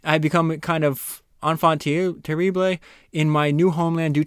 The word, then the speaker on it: enfant terrible: Enfant terrible.